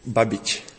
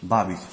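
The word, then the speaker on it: Babić: ¿Cómo se pronuncia? – Babich. – Babich, sí. Babich.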